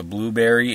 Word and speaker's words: Blubrry: It's not spelled like Blueberry, but if you type podcast in Blueberry, you'll find it easily. – Okay. – And you can just straight up hit it up. – Blueberry is spelled, actually, it's BLUBRRY.